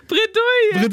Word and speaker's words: Bredouille: Wie spricht man das? – Bredouille. – Bredouille.